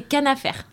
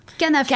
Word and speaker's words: Khanafer: C'est Canafer. Canafer. K-H-A-N-A-F-E-R.